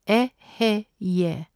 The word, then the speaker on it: Eheieh: In Keter, the God name is Eheye, spelt E-H-E-I-E-H. It is pronounced Eheye. It means I am. Chokmah, the God name is Yehovah, J-E-H-O-V-A-H, pronounced Yehovah, meaning the Lord God.